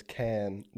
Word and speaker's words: Cannes: I think the French pronunciation is Cannes, because they also have a Cannes Media. Is it C-A-N-N-E-S? – Yeah. – Yeah, it's Cannes.